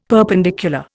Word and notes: perpendicular: Indian

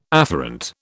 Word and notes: afferent: British English

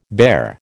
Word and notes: bare: American English

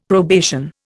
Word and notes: probation: Indian

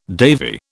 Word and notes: Davy: British English

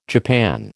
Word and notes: japan: American English

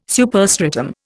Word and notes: superstratum: Indian

